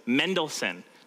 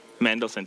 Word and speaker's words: Mendelssohn: So it was originally composed by the famous Felix Mendelssohn. Did I say that right, Chelsea? Mendelssohn. See, she's bugging me all week because I kept saying Mendelssohn. She's like, it's not Mendelssohn, it's Mendelssohn. Okay, so apologies if I say Mendelssohn at some point.